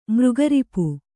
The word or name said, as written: ಮೃಗರಿಪು